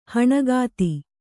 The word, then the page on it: ಹಣಗಾತಿ: Kannada